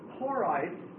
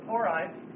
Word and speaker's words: Horites: But the significant thing about the Hurrians, spelled H-U-R-R-I-A-N-S, was that they are the long-lost Horites of the Bible. Horites. Sometimes you'll see it spelled like this, Horim. The Horim. But the Horites, spelled H-O-R-I-T-E-S, This is the home of the Horites.